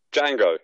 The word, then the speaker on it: Django: Django. You just don't pronounce the D. It's a silent D.